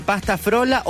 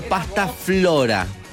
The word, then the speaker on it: pasta frola: Frola es la escritura, pronunciación original. Pero la RAE, sí, la Real Academia Española, también define la manera de pasta flora.